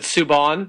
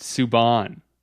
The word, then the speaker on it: Subban: Oh, is it Subban? – Yeah. So now he wants his name to be pronounced Subban.